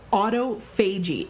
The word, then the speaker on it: autophagy: Now, it's spelled autophagy, A-U-T-O-P-H-A-G-Y, and lots of people mispronunciate this word, so you may not have actually heard this.